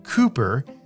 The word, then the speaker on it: Cowper: It's spelled Cowper, C O W P E R, but it's pronounced Cooper, and the poem was called Praise for the Fountain Opened.